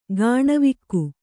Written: ಕಣೆವೋಗು

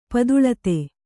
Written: ಪದುಳತೆ

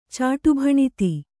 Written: ಚಾಟುಭಣಿತಿ

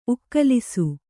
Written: ಉಕ್ಕಲಿಸು

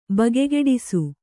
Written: ಬಗೆಗೆಡಿಸು

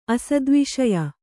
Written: ಅಸದ್ವಿಷಯ

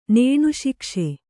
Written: ನೇಣುಶಿಕ್ಷೆ

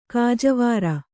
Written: ಗಜವೈರಿ

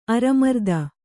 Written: ಅರಮರ್ದ

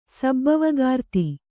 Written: ಸಬ್ಬವಕಾರ್ತಿ

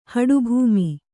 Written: ಹಡುಭೂಮಿ

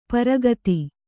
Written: ಪರಾಗತಿ

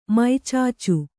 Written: ಮೈಚಾಚು

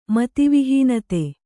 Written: ಮತಿವಿಹೀನತೆ

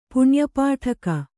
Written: ಪುಣ್ಯಪಾಠಕ

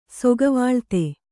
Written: ಸೊಗವಾಳ್ತೆ

